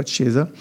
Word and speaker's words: Chiesa: E C-H-I-E-S-A. – Chiesa. Voilà, on ne dit pas Chiesa. Chiesa.